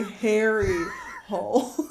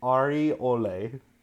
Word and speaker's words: Harry Hole: It's pronounced Ari Ole.